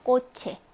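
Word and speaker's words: coche: 치읓 발음 납니다. 그래서 꼬체 이런 것들이 예가 되고요.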